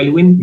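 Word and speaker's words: Aylwin: y arroba Ignacio Elwin, mi apellido se escribe AYLWI-N, y ahí pueden encontrar todos los links.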